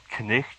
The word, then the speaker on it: knight: Yeah, which is kind of its own awkward experience, because back then, like, knight was actually the literal pronunciation spelling. What we call knight was called knicht, or something like that.